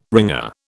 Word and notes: wringer: British English

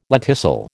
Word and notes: lenticel: American English

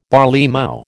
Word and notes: barleymow: American English

